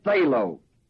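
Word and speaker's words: thelo (θέλω): The Greek word is the word thelo, spelled T-H-E-L-O, long O.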